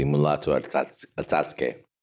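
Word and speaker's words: Mulatu Astatke: The standout stuff is by the Ethiopian sort of jazz musician Mulato Astatke. Correct me if I've mangled that. – You pronounced it correctly, Mulato Astatke.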